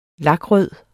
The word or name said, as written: TERNET